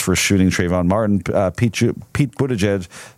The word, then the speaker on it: Buttigieg: This results from two separate tweets that both Warren and Buttigieg, that's the right way to say it, isn't it? – Yes, it is.